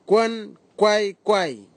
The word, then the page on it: kwankwaikwai: Nafe; Tanna